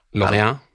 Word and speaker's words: Lorient: Lorient, er det riktig uttale? – Eh, ja, Lorient. Lorient.